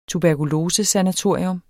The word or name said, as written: tuberkulosesanatorium